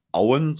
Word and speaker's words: Owen: Das kommt von der Bellerhof Brennerei und die sitzt in Auen, so wird es ausgesprochen. Genau. Geschrieben wird es Owen, also ja.